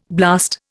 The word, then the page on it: blast: Indian